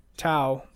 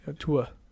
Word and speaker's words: Tua: He really, I mean, the quarterback, Tau, is that how you say his name? It's just Tau. Make sure I'm saying that right. Tua.